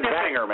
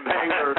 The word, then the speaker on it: Bangor: And Bangor, Maine. – What? – Bangor, Maine. – Bangor, Maine. – Well, actually, that's pronounced Bangor, but I'm quite a ways away from there. – Bangor.